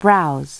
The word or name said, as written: browse